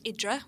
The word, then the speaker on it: Hydra: Yes, I was actually thinking about my favourite sunset cocktail that I've had, and it was in on a little island called Idra, which is spelled Hydra in Greece,